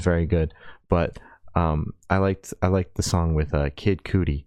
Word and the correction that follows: Kid Cudi: Come on, dude. Kid Cudi.